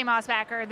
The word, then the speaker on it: Mossbacher: If you say Mossbacher, I say Mossbacher. This is the American bastardization of my name.